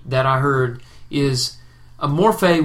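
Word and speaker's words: morphe (μορφή): Morphe and schema. Did I say that right? Yeah, morphe and schema.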